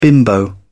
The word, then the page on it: bimbo: British English